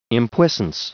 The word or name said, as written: impuissance